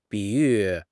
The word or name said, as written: 比喻